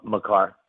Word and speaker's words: Makar: Uh, I call him Cal Maker, but uh, how do you say his name again? – M- Makar. The, Makar, thank you.